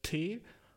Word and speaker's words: T.Volution: Also der Account heißt T.Volution, also aus, so, es wird ausgesprochen T-Volution, wie Revolution, nur mit T-Volution, was funny as fuck ist.